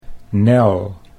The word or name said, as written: Nel